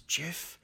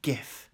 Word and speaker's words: GIF: it's soft G. I will fight you on this. It is jiff, not gif.